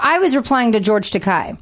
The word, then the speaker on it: Takei: It's George Takei rhymes with gay.